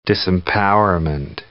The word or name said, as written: disempowerment